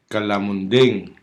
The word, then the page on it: kalamunding: Hiligaynon